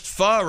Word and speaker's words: pho: And I just learned that pho, P-H-O, yes. – is pronounced pho.